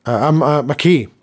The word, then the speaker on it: McKay: And I believe there was a famous quote by the their coach, Mr. McVay. McKay, I beg your pardon. I think they pronounce it McKay. We would say McKay, John McKay.